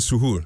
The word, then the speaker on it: suhoor: Pronounce it your the proper way. – Yeah, the suhoor.